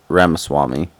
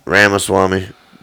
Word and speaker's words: Ramaswamy: Yeah, it's like if you look at the pronunciation on that, it's Ramaswamy. – Ramaswamy.